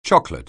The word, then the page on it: chocolate: anglais